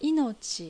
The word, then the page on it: Inochi: Japanese